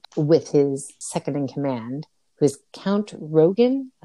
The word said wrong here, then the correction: Rugen: How do I say his name? Because you don't hear Is it Rugen? I think it's Rugen.